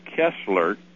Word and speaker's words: Koestler: called The 13th Tribe by Arthur Koestler, K-O-E-S-T-L-E-R, Koestler, I guess it is. Koestler, almost you want to say Koestler, but they pronounce it Koestler. Arthur, K-O-E-S-T-L-E-R.